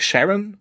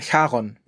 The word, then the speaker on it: Charon: Und er heißt nicht Sharon und er heißt auch nicht Charon, sondern Charon mit Ch.